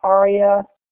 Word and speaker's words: ARIA: We'll talk about introductory ideas related to ARIA, A-R-I-A, which I will be pronouncing ARIA.